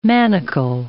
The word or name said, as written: manacle